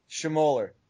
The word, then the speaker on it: Schmoller: How do you pronounce your last name? – Schmoller. – Nah, there you go. Schmoller. I always say, like, Schmiller.